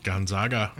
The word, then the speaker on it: Gonzaga: So Emmett attended Gonzaga Law School. – Gonzaga. Yeah. – Is that okay? Did I say it right? – Oh, yeah.